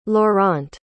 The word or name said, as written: laurent